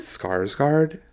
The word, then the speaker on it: Skarsgård: Like when you think of movies where it's like the main character doesn't speak, Bill Skarsgård... Is it Skarsgård? – Yeah. – I always feel like I'm saying it wrong.